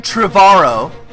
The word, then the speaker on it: Trevorrow: I like the sound of that original episode 9 writer Colin Trevorrow, rhymes with tomorrow, wanted to put in that movie a sword fight, a lightsaber fight between Darth Vader and Kylo Ren.